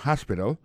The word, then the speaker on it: hospital: on dit ça en anglais hospital alors accent tombe sur premier syllabe là gain trois syllabes puis she likes to visit hospital Hospital l'a écrit H-O-S-P-I-T-A-L, hospital, l'hôpital.